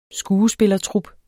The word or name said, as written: skuespillertrup